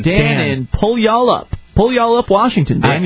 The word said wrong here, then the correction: Puyallup: Is that actually how you pronounce the name of where you're from? – It's Puyallup. – Puyallup. – Yeah. – Not pull y'all up? – That's no. How... Okay, yeah, there's not an extra L in it. Puyallup.